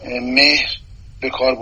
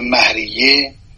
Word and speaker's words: مهریه: اما مهریه، من این رو اصلاح بکنم، مهر به کار برده نمیشه، تلفظ مهر درست نیست، مه از و مهریه. همه اساتید ما و قضات دادگاه و حقوقدان ها این کلمه را به صورت مهریه بر وزن شهریه تلفظ میکنم.